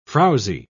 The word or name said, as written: frowzy